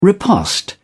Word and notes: riposte: British English

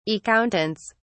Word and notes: accountants: British English

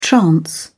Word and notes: trance: British English

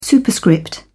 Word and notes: superscript: British English

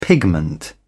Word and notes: pigment: British English

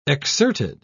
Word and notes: exserted: British English

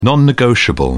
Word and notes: non-negotiable: British English

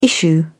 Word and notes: issue: British English

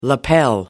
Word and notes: lapel: American English

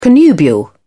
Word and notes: connubial: British English

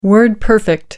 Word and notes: word-perfect: American English

